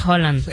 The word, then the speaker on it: Haaland: Dicen que su pronunciación es Haaland.